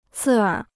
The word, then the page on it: 刺耳: cì ěr